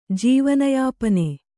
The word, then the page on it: ಜೀವನಯಾಪನೆ: Kannada